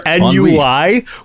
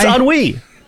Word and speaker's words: ennui: I hope that this show has cured your ennui, not your NUI, which is, it's not an acronym. It's a French word, and you're supposed to be.